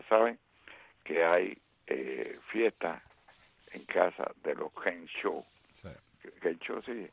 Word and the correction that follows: Kershaw: En buen americano, Kershaw. Okay, Clayton, bueno. Kershaw.